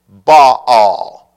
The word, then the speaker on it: Baal: That he married Jezebel, the daughter of Ethbaal, king of the Sidonians, and went to serve, I'm going to pronounce this, by the way, Baal. Okay? B-A-A-L. Some pronounce it Baal. Some pronounce it Baal. I'm from the south. I'm calling him Baal. All right? B-A-A-L.